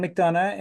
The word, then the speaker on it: McDonough: My name is John McDonough, and that's spelled M-C capital D-O-N-O-U-G-H.